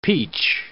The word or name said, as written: peach